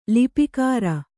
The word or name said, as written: ಲಿಪಿಕರ